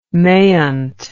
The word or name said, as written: maynt